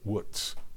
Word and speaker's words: Łódź: The Łódź Ghetto, that's spelled L-O-D-Z and pronounced Łódź.